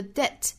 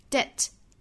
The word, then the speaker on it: debt: 仅仅就是上下唇碰触一下而已，好，不要发成 absolve, 不要再弹开，应该是 absolve, to absolve, 后面的 debt, 中间的 b 这个字母是不发音的，所以是 debt, to absolve debt。那说到一群划分，如果说这句话比较长的情况，我们可以在 debt 后面停顿一下， the power to absolve debt,